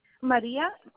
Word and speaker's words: Maria: Maria, M-A-R, I-A. And then Jimenez, J-I-M-E-N-E-Z.